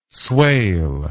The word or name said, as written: swale